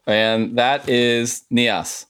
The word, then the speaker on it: Nias: And they are Nias? How do you pronounce that? – Yep. – Nias, okay.